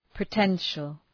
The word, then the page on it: potential: pə’tenʃəl